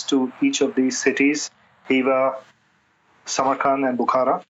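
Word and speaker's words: Khiva: The highlights of Uzbekistan are the cities of Hiva. It's spelled with a K, but the K is silent, so it's H-I-V-A is how you pronounce it.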